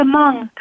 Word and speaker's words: Hmong: It's the Hmong community. It's spelled Hmong.